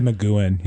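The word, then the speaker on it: McGoohan: Because I wasn't sure if it was pronounced McGowan. McGowan? Or McGowan. Because I would say McGowan, yeah.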